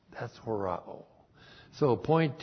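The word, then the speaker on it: Horao (ὁράω): And then we have the verb horao. Horao. H-O-R-A, long O.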